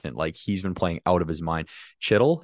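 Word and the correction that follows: Chytil: is that how you say it? – Heedle.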